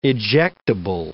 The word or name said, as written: ejectable